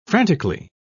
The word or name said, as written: frantically